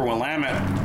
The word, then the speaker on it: Willamette: I don't know about you, but I always remember the pronunciation for Willamette from Rocky Horror Picture Show and the laugh track that people do it when they do it live, where they say, damn it, Janet.